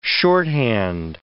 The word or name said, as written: shorthand